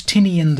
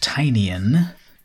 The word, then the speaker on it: Tynion: This is the latest work by James Tynion IV, which actually now I think it's pronounced Tynion.